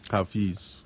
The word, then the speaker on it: Hafiz: I'd like to read this little piece from Hafiz. I think that's how you say it. – Mm-hmm. Hafiz. – Hafiz. – Yeah.